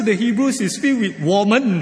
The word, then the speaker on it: women: Then I realized that W-O-M-E-N, women, in Han Yu Pinyin is not pronounced as women, it's pronounced as woman, right?